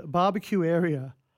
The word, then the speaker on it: Babakiueria: or a movie called Barbecue Area. Now, it's spelled B-A-B-A-K-I-U-E-R-I-A.